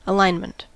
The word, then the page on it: alignment: American English